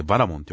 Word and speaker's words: 解体者: 個人的には今回、解体者って書いてバラモンって読むセンスがそんなに嫌いじゃなかったですけどね。